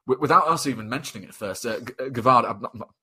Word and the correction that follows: Gvardiol: apologise for butchering the pronunciation, Gvardiol.